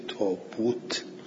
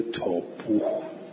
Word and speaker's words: تابوت: اختلاف بود، عثمان گفت که اونجور که قریش تلفظ میکنن بنویسید، تلفظ قریش تابوت بود، تلفظ مردم مدینه تابوه بود، بلزا تابوت نوشتند.